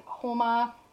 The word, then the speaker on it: Houma: We also have Daisy McGuire in Houma, and that's pronounced, or spelled H-O-U-M-A, Louisiana.